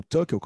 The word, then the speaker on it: Tuchel: É isso que eu vou perguntar agora para o Andrew se ele fosse o Tuchel, ou Tuchel, como se fala na Inglaterra, uh, quem ele colocaria.